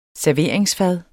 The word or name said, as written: SERVERINGSFAD